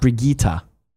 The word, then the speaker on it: Brigitte: It's not Bridget, it's Brigitte? – Brigitte. Brigitte.